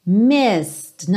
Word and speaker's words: missed: Missed, não é missed, então muito cuidado, não pronuncie esse E.